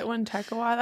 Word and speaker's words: Wintekawa: Anyways, so the word Wendigo probably comes from the Proto-Algonquian term, and I'm going to try to say this correctly, Wintekawa? – I think that's right, Wintekawa. That's how I would say it.